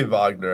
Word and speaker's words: Wagner: Guinea Gold F Base Wagner. PSA 6. – It's probably Wagner. I think that Jay called you out for the mispronunciation. – Wagner. I said that like a total American.